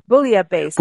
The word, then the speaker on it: bouillabaisse: National Bouillabaisse Day. – Bouillabaisse. Yeah. – Yeah. Bouillabaisse. So it's bouillabaisse. – Yeah. Bouillabaisse.